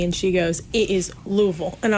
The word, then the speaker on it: Louisville: And Louisville.